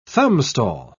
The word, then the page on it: thumbstall: British English